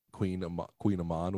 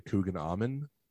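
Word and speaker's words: Kouign-amann: so a lady came in and she wanted the Queen Amon, which is like it's spelled C O U I G N or something, and then A M O N. It's like two words, and so she called it a Coogan Amon, and she's like, "Can I get the Coogan Amon?"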